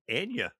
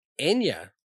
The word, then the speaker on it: Anya: But this was obviously, it was my first pick, and I had to go with Downtown by Anya Taylor-Joy. – I believe it's Anya Taylor-Joy. – Anya. – Yeah, I feel like we've been saying it wrong for so long.